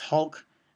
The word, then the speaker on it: HULC: Shortened to HULC, pronounced Hulk, the invention debuted in public stage in 2009.